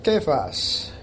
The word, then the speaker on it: Cephas: But when Cephas came to Antioch, that's the Aramaic pronunciation, by the way.